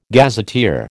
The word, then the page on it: gazetteer: American English